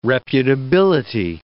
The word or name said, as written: reputability